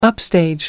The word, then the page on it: upstage: American English